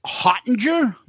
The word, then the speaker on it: Hottinger: Joe Hottinger, am I saying it correctly? – Yeah.